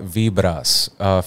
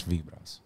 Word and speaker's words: Vibras: It's called Vibras, featuring Nas Lover, which is vibes in Spanish, Vibras.